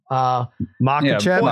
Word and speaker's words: Makhachev: Makhachev, is that it? – Yeah. – Makhachev, yeah. Right? M-A-K. – Yeah, correct? Makhachev, yeah.